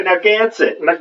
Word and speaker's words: Narragansett: Narragansett. – You gotta say it like Peter Griffin, Narragansett.